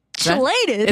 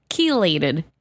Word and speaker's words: chelated: Is that how we say that word? Are we elated with chill? – Elated with chill. – It's got to be like a hard song. Where's my pronunciation? Let's see. Pronunciate this word. – Chelate. – Chelate. Chelated. That CH okay. makes a K sound there.